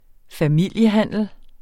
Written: familiehandel

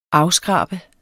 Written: AFSKRABER

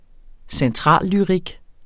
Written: centrallyrik

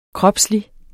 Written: kropslig

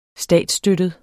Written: statsstøttet

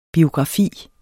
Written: biografi

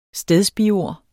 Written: stedsbiord

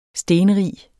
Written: stenrig

